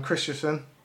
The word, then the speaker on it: Christensen: Christians, or whatever his name is. How do you say his name? – Sorry, I'm Christensen.